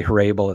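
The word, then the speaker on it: Hrabal: I don't know if it's Hrabel or Hrabel. I've heard it pronounced both ways. I say Hrabel. It sounds a little better, you know. – Yeah, rabble rouser, you know.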